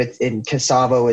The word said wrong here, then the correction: Kosovo: is Eastern Europe. – Oh, all right. Oh, Kosovo. – Kosovo, excuse me.